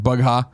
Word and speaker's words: Bugha: Booga. I always say his name wrong, I think. Bugha.